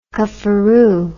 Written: cufferoo

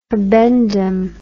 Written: habendum

